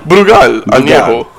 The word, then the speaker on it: Brugal Añejo: Say that again, Brugal. Am I saying that right? – Brugal Añejo.